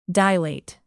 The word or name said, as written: dilate